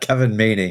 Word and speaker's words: Kevin Mahey: No, of course not. Kevin Mahey. – Dang it. All right. No, Sam's, wrong emphasis on the wrong syllable, and I just inserted a letter that wasn't even there.